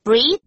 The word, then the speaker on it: breath: 所以注意哦，两个字长得很像 breathe, breath, 可是发音是不一样的。